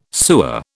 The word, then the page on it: sewer: British English